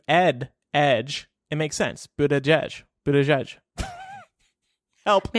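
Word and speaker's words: Buttigieg: He's saying we need to pronounce it Boot Edge Edge, but that does not roll off the tongue.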